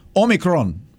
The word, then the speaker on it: Omicron: My brother, who of course is Greek like me, says it's vital that we all pronounce it the correct way, Omicron. So that is the official Greek pronunciation, and you're supposed to roll that R, and I didn't do a very good job of it there.